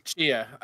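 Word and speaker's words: Tchia: Cause that one trailer for, I believe you say the name, T'Chia. – Yeah. – Is how you say that name? – Chia. Yeah. – Chia.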